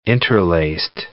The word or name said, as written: interlaced